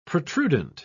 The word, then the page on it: protrudent: British English